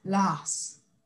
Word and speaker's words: Lars: De, hecho, no se pronuncia, no se pronuncia Lars, se pronuncia Lars.